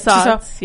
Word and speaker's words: Tussauds: Tussauds, and then it melted.